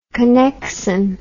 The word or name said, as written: connexon